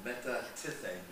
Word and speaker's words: metatithemi: So the Greek word there for deserting, it's spelled M-E-T-A-T-I-T-H-E-M-I. Do you want to give it a stab? – No. – Our resident Greek scholar is sitting in the room without a microphone. Say it loud. – Metatithemi.